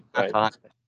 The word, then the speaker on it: Thich Nhat Hanh: Thai Chin something. – Thich Nhat Hanh.